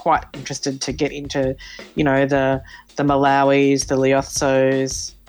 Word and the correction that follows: Lesotho: Did I even say that right? My pronunciation? – Lesotho. – Oh, really? Is that how you say it? – That's it. – Lesotho. Lesotho.